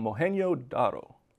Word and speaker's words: Mohenjo-Daro: It was in a Pakistani city settlement called Mohenjo-Daro. – That was a very good pronunciation of that.